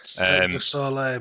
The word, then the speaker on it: Cirque du Soleil: I believe Reginald's like the guy who used to do like Cirque du Soleil. That's terrible pronunciation. You guys always butcher the French language. – Cirque du Soleil, mate.